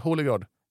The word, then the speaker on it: Holegård: Grå Holgård. Heter han så? Grå. – Holegård. – Holegård. – Ja, Holegård. Men vi kan väl börja med Robert då.